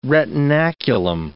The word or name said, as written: retinaculum